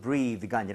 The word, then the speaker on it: breath: 발음도 breathe가 아니라 breath.